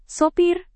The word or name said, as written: sopir